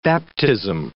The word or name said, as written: baptism